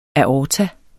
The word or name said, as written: aorta